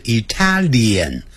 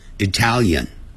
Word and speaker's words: Italian: We will try，就尝试 some 若干的一些 Italian，我们呢很快会念成 Italian，它发脸的音，哎，不是 Italian 这样念 Italian food。